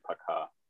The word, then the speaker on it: пока: Yeah, P-O-K-A with Poka. – Stress. – Mm-hmm. Okay. – But when O isn't stressed, it's pronounced as an A also, so it's basically Paka.